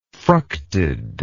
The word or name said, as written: fructed